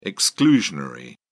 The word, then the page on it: exclusionary: British English